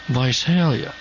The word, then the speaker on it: Visalia: That's how they say it when they live in Visalia, because they're all uppity. Visalia.